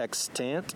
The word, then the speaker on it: extant: So in biology, we talk about extant versus, I try to pronounce it very clearly because it sounds so similar to its opposite term, which is extinct. Extinct is something that no longer exists. Extant means it's still present and still alive.